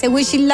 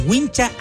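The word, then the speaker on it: huincha: Oiga, porque a todo esto no se dice wishing la hora. – ¿Cómo se dice? – ¿Wishing la hora? – Es wincha aisladora.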